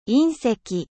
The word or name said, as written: meteorite